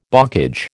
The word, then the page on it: bocage: American English